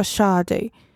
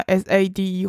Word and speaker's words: Sade: 我其实刚刚偷偷查了一下他的发音，他的英文叫 Sade，那我就觉得原来是因为这样才变沙袋。因为我觉得在我心中好像 S A D E 会变成另外一个发音，但是如果是念 Sade 的话，完全没错，就是沙袋。